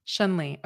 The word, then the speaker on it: Chun-Li: Chun-Li, okay.